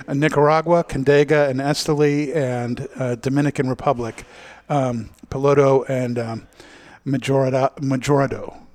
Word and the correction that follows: Mejorado: Did I say that right? – Mejorado.